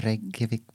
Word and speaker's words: Reykjavik: The Reykjavik Brewing Company.